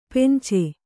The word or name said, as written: ಪೆಂಚೆ